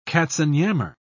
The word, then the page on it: katzenjammer: British English